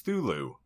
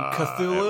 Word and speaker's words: Cthulhu: So it is pronounced Thulu. No, that's how I pronounce it, because the way that he phonetically wrote out the pronunciation when I've looked up Lovecraft's description, you can't pronounce it. It's intended to be an alien tongue.